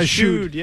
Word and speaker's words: eschewed: Three times the Patriots eschewed. Is that how you pronounce that? – Yeah, eschewed. Yes.